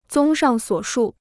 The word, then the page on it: 综上所述: zōng shàng suǒ shù